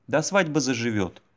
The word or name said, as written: до свадьбы заживет